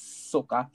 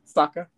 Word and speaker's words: Sokka: Ian Owsley as Sokka. Sokka? I said it wrong. Sokka.